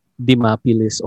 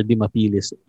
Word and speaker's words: Dimapilis: So whether people say it as Dimapilis or Dimapilis, either way, it's okay with me.